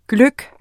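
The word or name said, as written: GLØGGEN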